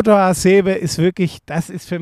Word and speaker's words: Hasebe: Er selber spricht sich eher so Hasebe aus, aber das klingt relativ albern.